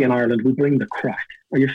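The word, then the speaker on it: craic: We spell it differently. It's C-R-A-I-C, crack.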